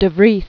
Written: De Vries

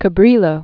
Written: Juan Rodriguez Cabrillo

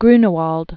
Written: Matthias Grunewald

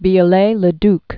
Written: Eugene Emmanuel Viollet-le-Duc

